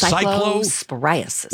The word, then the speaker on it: cyclosporiasis: How are we pronouncing that again? You're the official news person. – So it's cyclosporiasis.